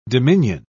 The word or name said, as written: dominion